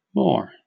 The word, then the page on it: moor: British English